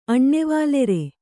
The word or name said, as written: ಅಣ್ಣೆವಾಲೆರೆ